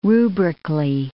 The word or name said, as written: rubrically